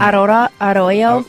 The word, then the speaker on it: arroyo: This morning, I mispronounced arroyo. – Mm. – I was saying... – Arroyo? – What was I saying? Arroyo? Arroyo. That's what I was saying. – Yep. – Arroyo. It's arroyo.